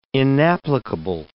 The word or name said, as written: inapplicable